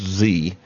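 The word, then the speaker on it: Xe: But Blackwater, now called Z, X-E, pronounced Z, is a private corporation.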